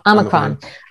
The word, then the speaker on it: Omicron: It's interesting because just one thing, how do you say Omicron? – Omicron.